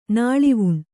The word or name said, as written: ನೆಲವೆಣ್ಣು